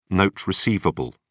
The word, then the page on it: note receivable: British English